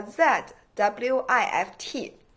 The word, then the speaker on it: Zwift: 嗯，就是呃，有一个软件叫 Z W I F T，然后发音我刚才现查了一下，叫 Zwift。